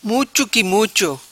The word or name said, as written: Muco Qui Mucho